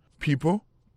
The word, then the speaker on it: people: Some nan se S-O-M-E. Some. People, P-E-O-P-L-E. Pronunciation correct is people.